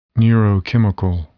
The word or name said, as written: neurochemical